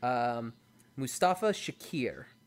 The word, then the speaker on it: Mustafa Shakir: You have Alex Hassel as Vicious, Daniela Pineda as Faye Valentine, and I'm gonna butcher your name. I apologize. Mustafa Shakir. – Oh no, that's it. You nailed it.